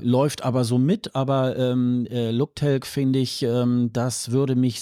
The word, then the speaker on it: Luktelk: Ich frage mich gerade, warum ich es Englisch ausgesprochen habe, weil es ist ja tatsächlich kein englischer Begriff, das heißt wirklich Luktelk.